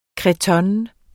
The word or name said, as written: cretonne